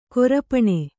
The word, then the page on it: ಕರಪಾಣಿ: karapāṇi